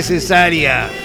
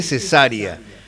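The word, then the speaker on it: Cesária: Acá está con acento en la segunda, en la primera, así que Cesárea, como la operación quirúrgica. Pero he visto que en algunos videos le dicen Cesárea, Cesárea Évora. Suena mejor, más africano, ¿no?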